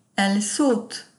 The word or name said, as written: elle saute